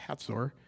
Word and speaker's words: Hazor: Well, in chapter 11, it came to pass that Jabin, the king of Hazor, or pronounced Hatzor, had heard these things.